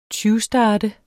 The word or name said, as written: tyvstarte